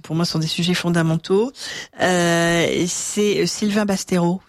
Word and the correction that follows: Bastonero: Vous étiez là? – Sylvain Bastonero. – Ah, Bastonero. Bastonero. – C'est pas grave. Oui, donc j'ai mangé une syllabe, c'est fou, quoi.